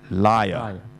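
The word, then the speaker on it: lyre: 我最近就真的为了这个节目，所以我都得益的，我真的 check 一下他、哦嗯、原来他是读 liar 即是直接是一个说谎者，这么上下的发音 liar、嗯、这个琴就是一个竖琴。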